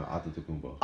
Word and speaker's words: Antetokounmpo: Antetokounmpo. Yeah, I think that's how it's pronounced. – No, Antetokounmpo.